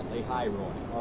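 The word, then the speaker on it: Lahai-roi: Oh, I think you're going to pronounce that. That's the right way right now, Lahairoi. All right? I got it right. Any other way I pronounce it is wrong.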